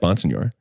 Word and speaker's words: Bonsignore: One world where I'm saying my name the way I've said it my entire life, Bonsignore. Yeah. And the other, saying it the way it should be pronounced and the way that you requested that it's our last name.